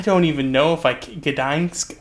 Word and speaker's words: Gdańsk: I think it's Gdansk. – The G is is it's a hard G.